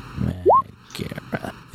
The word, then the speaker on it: Megara: Magma with an extra syllable. – Mm-hmm. Yeah. Megara.